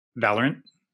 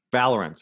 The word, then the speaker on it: Valorant: Valorant. – Valorant, sorry.